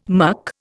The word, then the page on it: muck: Indian